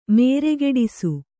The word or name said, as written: ಮೇರೆಗೆಡಿಸು